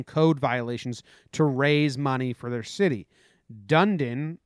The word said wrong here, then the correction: Dunedin: or Dundon, I don't know how you pronounce it. – Dunedin.